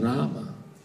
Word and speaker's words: Rama: Y no es ramo, es rama. Es una A, no es una O. Los bengalí cantan ramo, porque para ellos todas las A son no, ¿no? Por su dialecto, su pronunciación, su vocabulario. Pero Prabhupada era bengalí y nunca le escuchaba ninguna cinta grabada donde él cante ramo. Él siempre canta rama, ¿no?